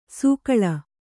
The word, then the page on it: ಸೊಗುಳಿ: Kannada